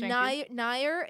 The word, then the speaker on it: Nire: I walk up and I go, Zill Nyer, that's, spell it. – Z-I-L for Zill. – And Z-I-L? – Then, yeah, and then Nyer is N-I-R-E.